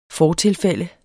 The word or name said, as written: fortilfælde